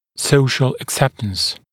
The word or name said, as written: social acceptance